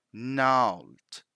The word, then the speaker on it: gnarled: Gnarled. Gnarled. So this one is a bit tricky, isn't it? Because we don't pronounce that G. So it's not gnarled, we simply say gnarled, gnarled.